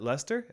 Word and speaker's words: Leicester: Leicester? Is that how you say it? – I'm pretty sure, yeah.